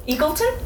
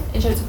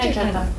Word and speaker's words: Egerton: ¿Eagleton? No, Egerton. Creo que la pronuncian Egerton.